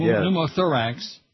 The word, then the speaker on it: pneumothorax: Pneumothorax.